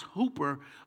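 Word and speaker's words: hooper (ὑπέρ): In the New Testament, in the Greek, this word intercede, it's the word intercano, but then in this particular text, it puts what is like the word hyper, but it's pronounced hooper intercano, and it means for someone's benefit.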